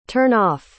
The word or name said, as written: turn-off